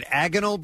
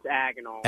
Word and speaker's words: agonal: Let me go to an EMT, wanted to comment on the, is it agonal breathing? Is that how you pronounce it? – Yeah, it's agonal.